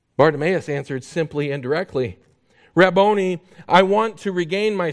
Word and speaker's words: Rabboni: In Jewish literature, Rabboni is how it would be pronounced.